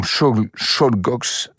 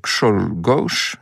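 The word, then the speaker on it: Czolgosz: Et voilà ce Léon Kshoglos. Je sais pas si j'arriverai à le dire. Ça s'écrit avec beaucoup de Z et de S, comme tous ces noms polonais. Kshoglos.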